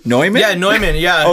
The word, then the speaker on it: Neumann: Well, and then it's funny because yeah, yeah, you'd think it was just Newman, but everyone says Neumann. So sure, yeah, I think Neumann.